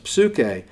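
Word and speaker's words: psuche (ψυχή): In English, the P is silent. In Greek, it's not. It's a psi, a letter of the alphabet in Greek, and it's pronounced psuche.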